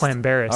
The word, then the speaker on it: Llanberis: Yeah, so and that's how it's pronounced? – Clambaris.